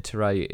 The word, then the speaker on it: Terrae: So man, and then Teray is T E R R A E.